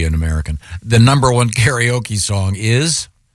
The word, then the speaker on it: karaoke: The number one, is it pronounced karaoke? – Yeah. – Technically? Okay. – A karaoke, actually.